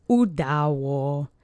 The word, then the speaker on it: udało: And the last word is udało, which is it succeeded. It's the past tense, third person singular of the verb. The accent is on the middle syllable.